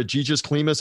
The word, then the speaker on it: Egis Klimas: the promoter, Egis Klimas, I believe is how you say it.